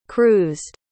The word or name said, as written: cruised